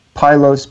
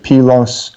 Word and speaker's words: Pylos: Pylos, P-Y-L-O-S. Sometimes people pronounce it Pylos. I think in America we mostly say Pylos.